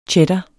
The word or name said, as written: cheddar